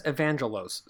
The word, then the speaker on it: Evangelos: Um, but this, but supposedly, he says Evangelos, so it is a soft G when he says it.